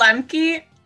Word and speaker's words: Lemcke: Rachel Lemcke.